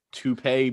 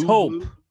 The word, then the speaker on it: taupe: Toupee blue? Tapu? Taupe. Taupe, nigga.